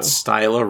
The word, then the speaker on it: Styla: You know what Styla rhymes with? – Hyla.